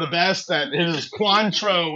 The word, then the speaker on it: Cointreau: Which yeah, we learned about triple sec replaces all those really fancy orange ones like the Cointreau.